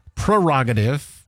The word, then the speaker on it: prerogative: Status is 38.1, so I checked, and you don't have to pronounce the first R, PR, prerogative.